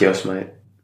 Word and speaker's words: Yosemite: It's Yosemite.